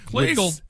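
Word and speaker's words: Kleagle: But this was the letter that the Imperial Kliegel, that's the name for the recruiter. – Kliegel rhymes with beagle.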